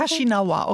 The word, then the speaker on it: Caxinava: Caxinava wird es ausgesprochen? – Caxinava, okay. Nicht, Portugiesisch ist es dann, ne? – Es ist sogar Hanchaquin, es ist eine indigene Sprache. Caxinava ist der Name dieses Volkes.